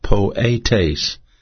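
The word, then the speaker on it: poietes (ποιητής): The word doer is pronounced poietes, and that's spelled P-O-I-E-T-E-S. And both of the E's are the long E's pronounced as an A, so poietes.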